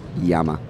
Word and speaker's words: llama: I believe it's pronounced Yama. – It is pronounced Yama.